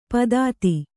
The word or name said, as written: ಪಾಥೋದಿ